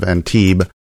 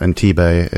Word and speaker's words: Antibes: We reached out, as we mentioned in one of the previous episodes, to the media relations of Antibes. I've been saying it wrong, too, this whole time. It's not Antibe, it's Antibe.